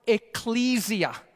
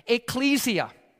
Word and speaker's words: ecclesia: Ecclesia. You can also pronounce it ecclesia. Ecclesia or ecclesia, kind of like tomato, tomato. Okay, even though tomato's the right one.